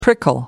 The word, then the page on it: prickle: American English